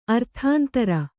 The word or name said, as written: ಅರ್ಥಾಂತರ